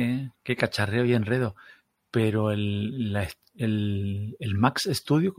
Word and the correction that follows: Mac Studio: ¿cómo es? Sí, ¿no? El Mac Studio. El Mac Studio, Mac Studio, sí, sí, sí, sí.